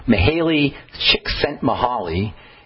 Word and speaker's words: Mihaly Csikszentmihalyi: Mihaly Csikszentmihalyi. Is how you say it, and I don't even know how to spell it, but that's how you say it.